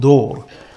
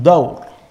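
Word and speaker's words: دور: And the third thing, which everyone is familiar with, it's a mispronunciation, we call it dor that word comes from dawr.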